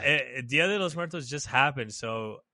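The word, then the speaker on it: Día de los Muertos: like, I think it's called like Dias de Muerte or something like that. I said that wrong for sure. – Oh, Dia de los Muertos?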